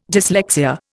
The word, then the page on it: dyslexia: Indian